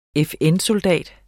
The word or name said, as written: FN-soldat